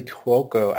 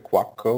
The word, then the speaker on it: Cuoco: And I did like Kaley Cuoco. I liked seeing her. Is that how you pronounce it? Cuoco? Is it Cuoco? Or I've always said Cuoco, so okay. We're on the same wavelength there.